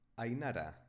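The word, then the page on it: Ainara: Basque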